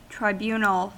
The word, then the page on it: tribunal: American English